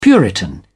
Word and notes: puritan: British English

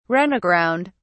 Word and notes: run aground: British English

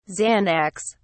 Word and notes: xanax: British English

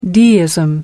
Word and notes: deism: American English

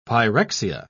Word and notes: pyrexia: British English

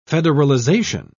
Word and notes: federalization: British English

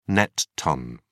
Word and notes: net ton: British English

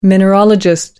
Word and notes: mineralogist: American English

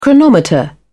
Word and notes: chronometer: British English

